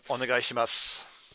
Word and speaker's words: Onegaishimasu: Onegaishimasu.